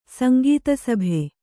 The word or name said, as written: ಸಂಗೀತಸಭೆ